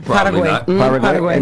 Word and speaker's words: Paraguay: Did I say that right? Paraguay? – Paraguay.